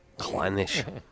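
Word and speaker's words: Clynelish: I know it's not how to pronounce it. You never pronounce anything in Scotland like it looks. – Clannish.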